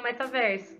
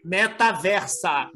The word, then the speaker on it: metaverso: Não, mas, mas eu quero, vou, vou, marcar esse rolê no metaverso. – Isso, o próximo papo Aliás... é no metaversa.